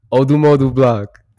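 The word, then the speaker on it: Odumodublvck: Next up, Fire Gun by Odumodu Block. That's how you say his name. Odumodu Block.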